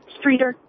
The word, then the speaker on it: Streator: Streator, Illinois. Is that how it's said? – Streator.